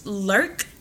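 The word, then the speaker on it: Locke: Lurk. Mm-hmm. That's how we pronounce it. You know, it's L-O-C-K-E. – Yeah. – So it's going to be Lurk for today.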